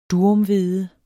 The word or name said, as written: durum